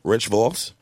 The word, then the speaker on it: Rich Vos: Rich Voss.